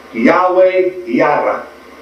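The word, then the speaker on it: Yahweh Yireh: The correct pronunciation from the Hebrew for Jehovah Jireh is Yahweh Yara, meaning Yahweh He will see to it.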